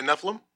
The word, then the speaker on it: Nephilim: Have you heard it pronounced like that? – Yeah, Nephilim.